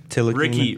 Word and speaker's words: Ntilikina: I believe the official ruling is it's the N, that you pronounce the N. – So the T is silent. – Can we just do French Frank? – Ntilikina.